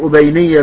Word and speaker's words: أبينية: قوله أبينية بضم الهمزة وفتح الباء الموحدة وسكون ياء التصغير وبعدها نون مكسورة ثم ياء النسب المشددة كذا قال ابن رسلان في شرح السنن.